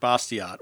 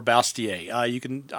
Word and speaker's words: Bastiat: Now, and again, Chuck, you mentioned a little bit earlier a writer by the name of Frederick Bastiat or Bastiat. I've heard it pronounced both ways.